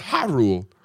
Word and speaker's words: Ja Rule: Do you mean Ha Rule? It's pronounced Ha Rule.